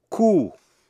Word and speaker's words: coup: Receipt, R-E-C-E-I-P-T, trong đó chữ P cầm, giấy biên nhận, đọc là receipt. Coup, C-O-U-P, coup là cuộc đảo chính, trong đó chữ P cầm. Corps, C-O-R-P-S, corps, là một sư đoàn.